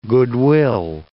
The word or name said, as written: goodwill